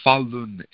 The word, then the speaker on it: Falun Gong: And because the Falun Gong, and it's pronounced Falun, F-A-L-U-N, and G-O-N-G, Falun Gong movement,